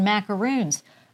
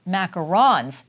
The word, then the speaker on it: macarons: So these little cookies here, I thought these were called, the little cookies with the chicks on, I thought they were called macaroons. Oh, no, no, no. They're called macarons.